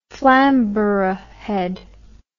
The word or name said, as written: flamborough head